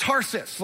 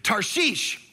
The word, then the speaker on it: Tarshish: So, but Jonah instead, he ran away from the Lord and he headed for, and again, this is first offense, it's not Tarsus. Like that's how we grew up learning. It's not even Tarsish. It's Tarshish is how you say this.